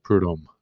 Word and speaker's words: Prudhomme: So we're hoping that we get the pronunciation of Prudhomme correct.